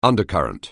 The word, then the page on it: undercurrent: anglais